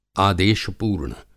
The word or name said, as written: आदेशपूर्ण